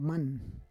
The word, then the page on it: man: Roon